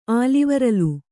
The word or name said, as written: ಆಲಿವರಲ್